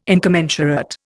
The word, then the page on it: incommensurate: Indian